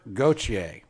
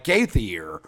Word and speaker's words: Gauthier: Cutter Gauthier. And if you pronounce it phonetically, it's like Gaithier. – Yeah. Not like the singer.